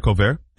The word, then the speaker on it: haricot: What is it? Haricot. Is that how you say it? – Yeah. – Yeah.